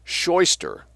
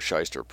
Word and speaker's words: Scheuster: A Clinton Street lawyer named Scheuster, S-C-H-E-U-S-T-E-R, or Scheuster or Scheister pronounced. In German it would be Scheuster, it might be Scheister in Yiddish, but it became Scheister in English in any case.